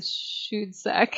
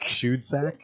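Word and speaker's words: Schoedsack: How do you say that name? – Yeah, I'd go with Shudsack. – Shudsack.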